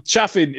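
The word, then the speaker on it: chafing: like cha- chaffing is the acceptable pronunciation. and I refuse to uh, back down on that.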